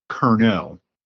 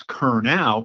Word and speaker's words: Curnow: And that's because even within our immediate family, it gets pronounced both ways all the time. Interestingly, from what I've been told, the correct pronunciation is Curnow. – Okay. – Yet I tend to use Curnow, particularly when I'm meeting somebody new, because phonetically it makes more sense, like the way it's spelled, C-U-R-N-O-W. People get it.